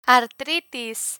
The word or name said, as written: artritis